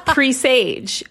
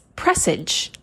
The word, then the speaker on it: presage: I thought it was presage, but it's presage.